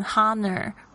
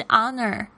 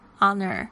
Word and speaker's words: honor: An honor 这边会发现说，这边怎么会加上这个 an 而不是呢，因为这 honor 其实呢，这个 h 是没有发音的哦，不要念成 honor 会很好笑，是 honor honor。